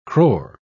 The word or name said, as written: crore